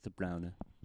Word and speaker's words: De Bruyne: That's a mispronunciation, by the way, from English commentators. It's De Bruyne.